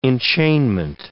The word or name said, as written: enchainment